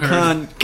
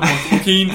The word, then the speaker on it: Joaquin: This is how you say it, Jaquan. – No, all right, Joaquin. All right, for real though, Joaquin Phoenix, Amy Adams.